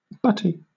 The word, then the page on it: butty: British English